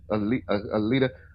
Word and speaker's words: Alita: But also, we're going to talk about Alita. Am I saying that right? – Yeah, Alita.